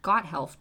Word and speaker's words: Gotthelf: Gotthelf, G-O-T-T-H-E-L-F. – Thank you. – Jeremias, J-E-R-E-M-I-A-S, Gotthelf, G-O-T-T-H-E-L-F, wrote a story called Die Schwarze Spinne, D-I-E-S-C-H-W-A-R-Z-E-S-P-I-N-N-E.